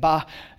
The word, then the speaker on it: Baal: I have a whole sheet of study on Baal, and that's how you say it, Baal.